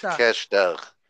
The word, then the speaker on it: Takeshita: Takeshita. – Takeshita.